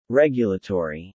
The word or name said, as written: regulatory